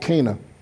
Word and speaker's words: Cana: It's pronounced Cana, by the way, amongst the natives. Here in America, we call it Cana, always have, but it's Cana.